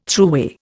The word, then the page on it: thruway: Indian